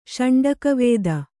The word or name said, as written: ಷಂಡಕವೇದ